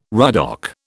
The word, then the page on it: ruddock: British English